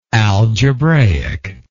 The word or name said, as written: algebraicly